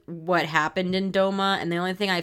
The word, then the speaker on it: Douma: Yeah, Douma near Douma.